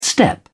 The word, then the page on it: step: British English